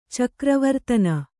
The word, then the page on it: ಚಕ್ರವರ್ತನ: cakra vartana